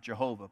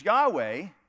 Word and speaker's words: Yahweh: Some people translate or pronounce it Jehovah, but Yahweh is the right pronunciation.